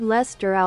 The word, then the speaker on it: Leicester: So, wie spricht man das aus? Los, komm, erzähl mal. Wo ist das? Hier ist das. Los, erzähl mal. – Lord Leicester aus Manchester.